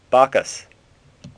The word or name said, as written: Bacchus